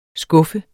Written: skuffe

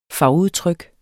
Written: fagudtryk

